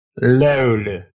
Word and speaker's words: Löyly: Löyly.